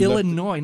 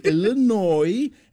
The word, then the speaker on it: Illinois: And she Illinois. Now, don't pronounce the S. – Okay, I'm learning. Illinois.